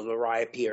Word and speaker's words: liriope: And now I say liriope. Some people say lirio.